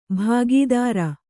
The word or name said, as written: ಭಾಗೇದಾರ